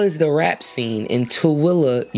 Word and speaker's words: Tooele: Tooele. So like, it's like, it's pronounced like T-O-W-I-L-L-A, Tooele. – But it's spelled T-O-O-E-L-E.